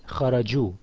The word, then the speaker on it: خرجوا: We added a waw and after it, خرجوا. The alif, of course, was simply in writing, not in pronunciation.